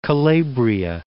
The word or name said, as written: Calabria